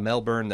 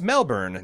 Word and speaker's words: Melbourne: I have some points about Melbourne that might help you out. First, it's pronounced Melbourne, not Melbourne, like most Americans pronounce it.